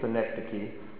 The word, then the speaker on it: synecdoche: If you want, you can call it synecdoche, but I think it's synecdoche is the way it's supposed to be pronounced.